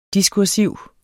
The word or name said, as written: diskursiv